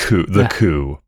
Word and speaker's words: Coup: I would pronounce the band's name. That's not how it's pronounced. – Coup. But again, don't know 100%. – It's the coup for sure.